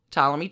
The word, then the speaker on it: Ptolemy: She attempts to take her daddy, Ptolemy 12, out of the running. It's pretty bad. – Permanent silent P.